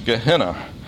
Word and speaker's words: Gehenna: The term that James uses for hell is Gehenna, sometimes pronounced Gehenna in English.